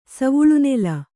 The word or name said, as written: ಸವುಳುನೆಲ